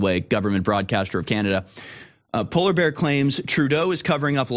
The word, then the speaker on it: Poilievre: and calling Pierre Poilievre, I don't know how to pronounce his last name, Pierre P, is that what we should call him? Poilievre, like polar bear?